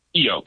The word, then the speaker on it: Keyo: Keyo, K's like a key with an O on the end.